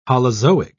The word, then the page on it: holozoic: British English